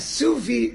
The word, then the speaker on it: sous vide: The Suvi Shaila. It's a French word, and the French spell it S-O-U-S space V-I-D-E.